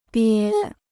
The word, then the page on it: 憋: biē